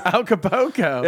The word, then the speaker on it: Acapulco: Acapulco? – Acapulco.